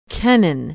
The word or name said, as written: kennan